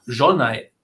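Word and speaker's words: Zsolnay: Und zwar das Museum, meine ich jetzt, was auf jeden Fall einen Besuch wert ist, das Jolnay, Jolnay Museum. Habe ich richtig gesagt, oder? – Genau. Stolnay-Museum,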